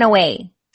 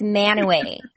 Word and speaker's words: Mantua: Manaway. Mantua is how it's spelled, but it's Manaway.